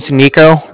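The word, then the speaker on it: Niko: My name's Nico. That's N-I-K-O. Calling from the Bank of Cardiff. That's spelled C-A-R-D-I-F-F.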